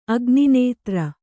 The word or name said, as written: ಅಗ್ನಿನೇತ್ರ